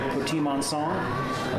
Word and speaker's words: Petit Manseng: It's spelled Petit Manseng, but the French pronounce it something more like Petit Manseng, a very popular grape, an up-and-coming grape in Arizona, and I think it's great with great potential here in Arizona.